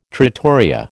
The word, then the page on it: trattoria: American English